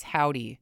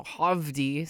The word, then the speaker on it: Hovvdy: This is Howdy. It's spelled Hovvdy. So Hovdy, sometimes my brain goes to.